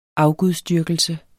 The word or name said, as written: Afgudsdyrkelse